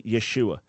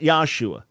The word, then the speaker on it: Yeshua: The correct pronunciation of Jesus' name, the way it would have been pronounced when he was called, when others spoke to him, it was a very, very common name in his day, would be Yeshua, not Yahshua. There's no such name as Yahshua, never has been. It's not a Hebrew name. Yeshua would have been the way it was pronounced.